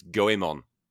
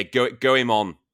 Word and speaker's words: Goemon: It's not Goemon. It's Goemon. Goemon.